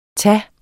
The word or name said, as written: tage